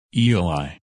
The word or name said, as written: Eli